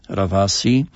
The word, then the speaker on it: Ravasi: Mniejsza z tym o wydawnictwo, teraz nie pamiętam tegoż wydawnictwa, ale o ile sobie dobrze przypominam, ta książka pod tytułem Dusza Ravasi, pisze się tak, jak się słyszy, V w środku. Jak państwo wpiszą w internecie nazwisko Ravasi, prawda? – Przez dusza, przez S właśnie, Ravasi.